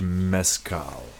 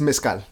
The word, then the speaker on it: mezcal: Single Village Mezcal. You pronounce it. Say it again. – It's mezcal.